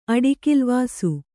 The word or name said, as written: ಅಡಿಕಿಲ್ವಾಸು